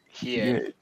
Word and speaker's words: Hiei: Fucking Hiei. How do you pronounce this? – Hiei.